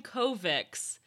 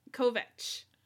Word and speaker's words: Kovacs: Now, you're saying Kovacs. But we are specifically told that's not how you pronounce his last name, and he gets very annoyed when people pronounce it wrong. – That's right. It's supposed to be with a CH, but I listened on the audiobook, Kovic.